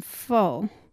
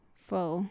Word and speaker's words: pho: pho.